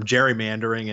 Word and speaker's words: gerrymandering: The soft or the hard G became a soft G over time. And so that's the origin of the term gerrymandering.